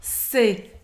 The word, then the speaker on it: c'est: Quando a gente não tem um sujeito na frase em português, a gente vai sempre usar se em francês, tá? Se. E o, a pronúncia é como se fosse somente a letra C.